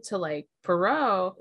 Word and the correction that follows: Piero: Is what how do you say it again? – I don't remember. – Piero. Okay. – Yeah. P in a row. I remember now. Piero.